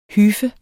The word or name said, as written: hyfe